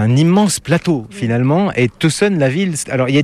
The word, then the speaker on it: Tucson: Ah, tout le monde dit ça, mais le C, c'est sillon. Alors, s'il vous plaît, quand vous venez ici, il faut prononcer Tucson.